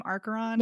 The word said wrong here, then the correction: Acheron: There's no Archeron. It's Acheron or Acheron. There's no R.